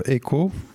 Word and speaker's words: Aequo: Oui, alors il s'agit du groupe Echo qui s'écrit A-E-Q-U-O.